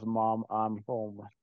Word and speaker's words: Mom I'm Home: Jag är inte riktigt inne på Mumin Home här. Eh, som kanske ska uttalas Mom I'm Home. Eh, men eh, det är mycket roligare med Mumin Home.